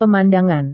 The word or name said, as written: pemandangan